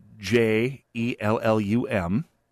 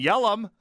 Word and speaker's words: Jellum: And you can guess that they were both pronounced differently. Oh yeah, one of them was Jellum, and the other one was Yelly and they're both different corruptions from the original Scandinavian, uh, but they had their preferred corruption.